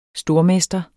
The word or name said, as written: stormester